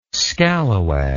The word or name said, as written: scallawag